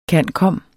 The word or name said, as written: cand.comm.